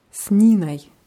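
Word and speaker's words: с Ниной: Sninoi. Die Betonung ist wieder auf I, also wird Oi eher wie Ei ausgesprochen.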